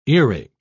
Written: earache

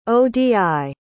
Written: odi

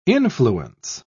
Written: influence

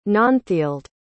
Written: nanthild

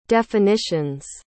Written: definitions

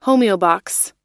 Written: homeobox

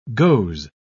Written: goes

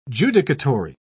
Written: judicatory

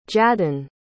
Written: jadon

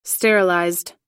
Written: sterilized

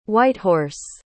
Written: whitehorse

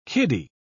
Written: kiddie

